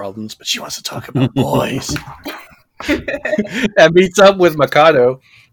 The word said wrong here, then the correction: Makoto: Did I say that right? Mikado? – Makoto, yeah.